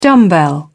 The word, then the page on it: dumbbell: British English